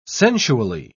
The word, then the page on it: sensually: British English